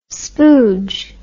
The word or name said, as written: spooge